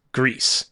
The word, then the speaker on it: Gris: Is that how this is pronounced? Mm. Gris? It is.